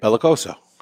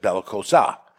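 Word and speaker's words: belicoso: Say it. Bellicoso. You said Bellicosa.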